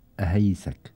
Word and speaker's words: أهيسك: في أبو راش أبو سعد بيقول لي كيفية نطقها، يعني بيقول أهيسك، هكذا تنطق يا أبا أحمد؟ أهيسك، شكرا. أو يعني أو ذاكر الجواب، شكرا جزيلا لك. إذن أهيسك هي تنطق بهذه الطريقة حسب رأي أبو سعد، مشكورا.